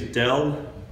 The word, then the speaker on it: Del: My name is Del Bigtree. That's first name D-E-L. My last name is Bigtree. B-I-G-T-R-E-E.